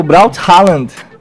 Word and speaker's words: Braut Haaland: Meu destaque vai pro Braut Haaland. – Aí a pronúncia saiu, mano. Isso aí.